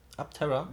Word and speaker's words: Aptera: Die nächste Band auf der Liste ist Abtera Aptera. Wie schreibt man das? A-P-T-E-R-A.